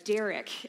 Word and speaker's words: derech: The way that how they live is translated is actually coming from this word that means that is a derech in the Hebrew. Say derech. There you go. I like that. Derech. Great pronunciation there.